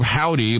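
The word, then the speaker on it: Hovvdy: pronounce the name of that group Howdy, but it's spelled with two V's, H-O-V-V-D-Y.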